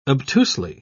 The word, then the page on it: obtusely: British English